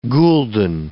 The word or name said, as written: gulden